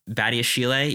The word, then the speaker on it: Badiashile: Badiashile.